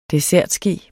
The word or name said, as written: dessertske